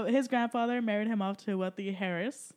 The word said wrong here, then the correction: heiress: Heiress. – Okay, let me try again. – The H is silent.